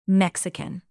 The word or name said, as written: mexican